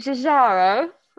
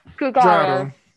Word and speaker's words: Giugiaro: Yeah, the Giugiaro. – That's the way to do it. – Giugiaro.